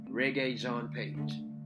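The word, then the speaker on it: Regé-Jean Page: Jordan, that I think that could potentially play Superman is Rege Jean Page. I said the name right. Rege Jean Page.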